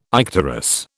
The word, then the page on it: icterus: British English